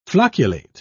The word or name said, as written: flocculate